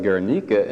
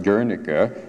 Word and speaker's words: Guernica: It is, I should hasten to say, called Guernica in Spanish. That's the Spanish pronunciation. But in the English-speaking world, for better or for worse, it seems to have been altered to Guernica or Guernica. So you have your choice among those pronunciations, whether you want to be Iberian or Anglo-Saxon.